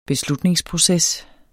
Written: beslutningsproces

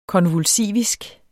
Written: konvulsivisk

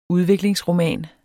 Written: udviklingsroman

